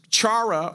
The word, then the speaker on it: chara: However, the most common New Testament word for joy is anglicized or English version is chara or akara. It's C-H-A-R-A or X-A-R-A.